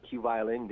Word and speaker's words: QVLN: If you just look up my name, which is spelled with 4 letters, QVLN, Q Violin, then you'll find all my latest releases.